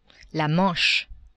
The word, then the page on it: Manche: French